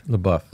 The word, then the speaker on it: LaBeouf: Like this one lady who is Shia LaBeouf's, is that how you say his last name? LaBeouf? – LaBeouf.